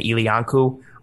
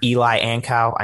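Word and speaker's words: Eli Ankou: I would really love to see Star, Ed, Harrison Phillips, and then Ilyanku. It's not Eli Ankow. I know a lot of people say it like that. It's Ilyanku.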